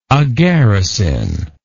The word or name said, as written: agaricin